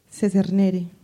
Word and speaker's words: Secernere: Apenas hace unos días averigüé la pronunciación correcta del nombre. Echernere es una palabra que viene del latín y significa separar. Se escribe así. – S. E. C. E. R. N. E. R. E.